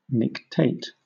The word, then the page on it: nictate: British English